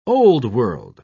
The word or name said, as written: old-world